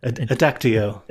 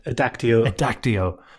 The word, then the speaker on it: Adactio: is it Adactio? – Adactio. – How do you pronounce it? – Adactio.